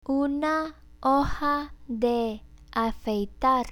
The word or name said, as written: una hoja de afeitar